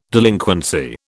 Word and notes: delinquency: British English

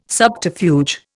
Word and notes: subterfuge: Indian

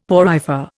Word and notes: porifer: Indian

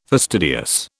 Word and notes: fastidious: British English

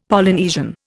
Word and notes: Polynesian: Indian

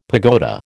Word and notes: pagoda: American English

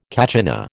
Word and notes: kachina: American English

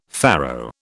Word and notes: faro: British English

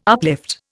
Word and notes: uplift: Indian

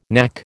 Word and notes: knack: American English

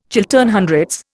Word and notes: Chiltern Hundreds: Indian

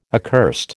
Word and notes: accursed: American English

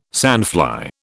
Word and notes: sandfly: British English

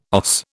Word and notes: OS: British English

